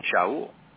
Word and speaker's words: Shaul: He says, "But, you know, I even named my youngest son, his middle name is the Hebrew pronunciation, Shaul."